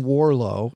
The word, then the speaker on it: Warrillow: There is a resource there that I like, a guy named John Warlow. That's how you pronounce his name. If you're spelling it, it looks like Warillo, W-A-R-R-I-L-L-O-W.